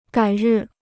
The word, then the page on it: 改日: gǎi rì